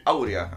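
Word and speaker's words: Áurea: Errores Geniales que Cambiaron el Mundo, de Mario Livio, autor del bestseller internacional La Proporción Aurea. Uy, Aurea, actually. Eh, tiene acento en la La Proporción Aurea, de Mario Livio.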